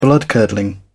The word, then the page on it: bloodcurdling: British English